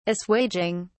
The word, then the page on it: assuaging: British English